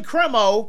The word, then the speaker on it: Cremo: I keep saying Cremo because that's how it's pronounced.